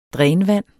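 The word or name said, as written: DRÆNVANDET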